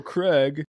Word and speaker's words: Krieg: But General Krieg, and I would have not pronounced it Krieg. I would have pronounced it Craig based on how it's spelled.